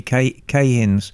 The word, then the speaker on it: Keihin: They may be Cahins, but they're pretty gruesome carburetors. – How do you say that? I thought it was Keihin. – Cahin. K-E-I-H-I-N.